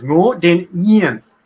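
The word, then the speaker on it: Ngô Đình Diệm: The Y, the D in Vietnamese is always pronounced like a Y. That's just part of our reality now, right? So it's Ngo Dinh Yen.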